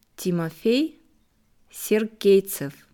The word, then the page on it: Timofey Sergeytsev: slow